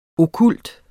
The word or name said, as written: okkult